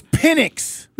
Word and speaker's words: Penix: It's Penix.